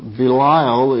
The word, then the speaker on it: Belial: Interesting that Belial is just a noun, just a name. And it is a name that was created from the Hebrew word, pronounced the same, Belial.